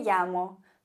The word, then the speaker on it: llamo: Und Doppel-L wird im Spanischen wie ein J ausgesprochen. Zumindest im Castellano. In Argentinien würde man sagen, me llamo. Aber in Spanien sagen wir, me llamo.